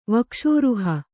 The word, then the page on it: ವಕ್ಷೋರುಹ: kannada